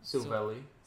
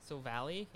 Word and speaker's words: Silvally: Silvally. – Silvally, yeah.